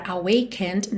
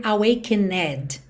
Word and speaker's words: awakened: Muito importante você reparar que esse E final dessa palavra awakened não é pronunciado. Não é mesmo, então você não precisa nem se preocupar com ele. Você nunca vai dizer awakened, tá? ND direto.